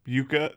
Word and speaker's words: yuca: And then there's YUCA, which is like a potato, and that's pronounced yucca.